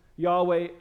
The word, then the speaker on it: Yahweh: Again, I believe Yahweh is closer to the actual pronunciation.